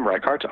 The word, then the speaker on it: Reichart: My first name, Reichart, R-E-I-C-H-A-R-T.